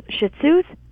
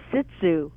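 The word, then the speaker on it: shih tzu: You know the little dog's shih tzus? – Uh-oh, I'm scared to ask. – My dad would call it a sit tzu. – Oh, sit tzu. – But it's pronounced zoo.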